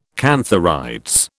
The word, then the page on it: cantharides: British English